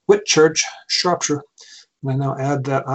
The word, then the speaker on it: Shropshire: Am I pronouncing that right? Shropshire? – Shropshire, that's right.